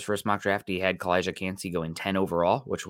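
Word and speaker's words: Calijah: Kalija with the J, the hard J on there. Okay. – The Portuguese J.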